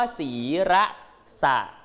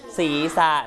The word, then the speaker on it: ศีรษะ: ศีระสะเราจำเวลาที่เราเขียนแต่จริงๆแล้วมันอ่านว่าอะไรลูกศีรษะนะอย่าอ่านว่าศีระสะล่ะอ่านว่าศีรษะแ